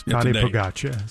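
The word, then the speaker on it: Tadej Pogačar: Yes, Tade Pogacar is how you pronounce that.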